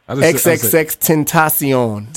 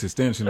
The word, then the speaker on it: XXXTentacion: I believe I said it right. I think I pronounced it right. Tentacion. – I thought it was XXX extension or something. Like that. – Me too. I thought it was extension.